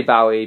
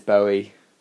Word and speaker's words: Bowie: A lot of people say Bowie, but it's Bowie.